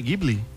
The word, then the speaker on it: Ghibli: A pronúncia é o quê? Ghibli? – Ghibli, é. – Ghibli, né? – É.